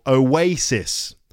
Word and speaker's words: Oasis: In English, it's a three-syllable word, and the stress is on the second syllable, Oasis.